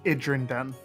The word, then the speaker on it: Idrinden: It's Idrinden.